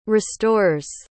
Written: restores

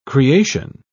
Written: creation

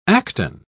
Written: acton

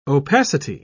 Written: opacity

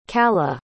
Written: callough

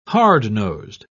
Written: hard-nosed

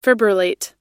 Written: fibrillate

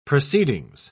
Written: proceedings

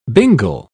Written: bingle

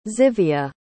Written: tzivia

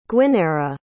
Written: gwyneira

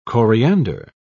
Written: coriander

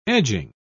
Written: edging

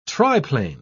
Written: triplane

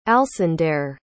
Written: alsandair

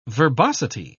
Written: verbosity

